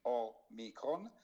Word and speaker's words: Omicron: nasce questo nuovo Omega e Omricon. Dimmi il titolo giusto, l'ho detto giusto. Sì, sono le due lettere dell'alfabeto greco, la O grande, quindi omega, e la O piccola, quindi Omicron.